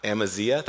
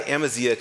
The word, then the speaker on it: Amaziah: And then there's this guy, Amaziah. That's how I'm going to pronounce it. That's not the Hebrew way. But this guy Amaziah comes on the scene and he says this.